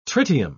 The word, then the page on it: tritium: British English